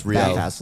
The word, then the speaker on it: Riel: It's Riel.